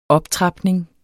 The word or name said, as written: optrapning